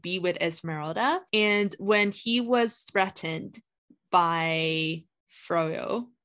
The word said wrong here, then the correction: Frollo: I hope that's how you pronounce his name. – Frollo? – Frollo. I keep saying Froyo because I thought that it was like the Spanish way.